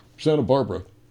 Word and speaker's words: Santa Barbara: He was named Potable because his father and mother were building a home over here in Santa Bagabadia. I can't pronounce it. – Santa Barbara.